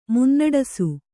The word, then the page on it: ಮುನ್ನಡೆಸು: munnaḍesu